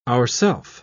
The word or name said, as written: ourself